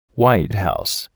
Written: White House

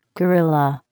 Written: gorilla

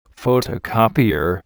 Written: photocopier

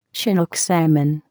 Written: chinook salmon

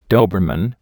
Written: Doberman

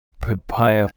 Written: papaya

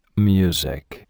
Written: music

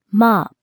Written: mop

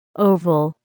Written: oval

